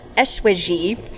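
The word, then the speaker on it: Eschwege: When John Adam Lemp arrived in St. Louis from Eswege, however you pronounce that, Germany. – I think you did it perfectly. – I don't think I did.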